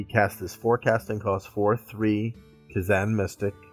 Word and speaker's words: Kazan: The Kazistan Mystic? Is that how it's pronounced? – The Kazan Mystic.